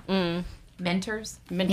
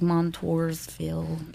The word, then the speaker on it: Montoursville: Mentorsville. – Yeah, because it's not spelled like that. It's like Montoursville.